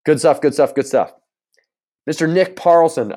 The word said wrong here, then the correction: Parslow: Oh, just, no, not Parlson, Parslow. Is that how you say it? It's got to be P-A-R-S-L-O-W.